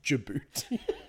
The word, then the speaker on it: Djibouti: It's pronounced Djibouti.